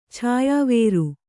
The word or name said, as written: ಛಾಯಾವೇರು